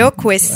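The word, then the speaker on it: Okwess: Jupiter et Ones. – Et Oquest. – Et Oquest, bon ben voilà, merci beaucoup.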